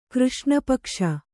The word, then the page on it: ಕೃಷ್ಣಪಕ್ಷ: křṣṇa paksha